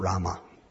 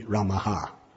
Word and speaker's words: Ramaha: it's not Rama. It's Sri Ramaha. So even you're saying it wrong.